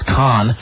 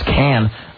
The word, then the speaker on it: Cannes: Which people mispronounce as Con. It is Cannes.